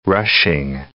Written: rushing

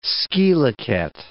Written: scilicet

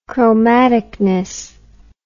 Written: chromaticness

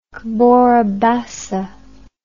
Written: Cabora Bassa

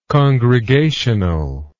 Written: congregationally

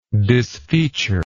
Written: disfeature

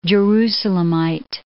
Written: Jerusalemite

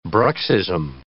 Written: bruxism

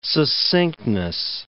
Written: succinctness

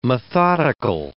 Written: methodical

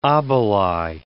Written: obeli

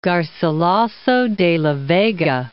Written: Garcilaso de la Vega

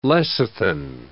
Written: lecithin

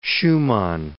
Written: Schuman

